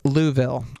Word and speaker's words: Louisville: Oh, excuse me, whoever texted in, guys, it's pronounced Louisville. – Oh, my bad, Louisville. – Whatever. – Louisville. It is Louisville.